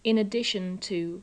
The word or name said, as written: in addition to